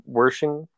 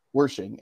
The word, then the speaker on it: Wershing: Wershing. – Wershing.